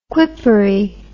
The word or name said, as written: quippery